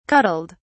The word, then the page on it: cuddled: British English